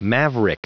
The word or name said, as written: maverick